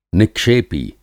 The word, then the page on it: निक्षेपी: Hindi